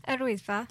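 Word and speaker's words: Yr Wyddfa: Erwisfa.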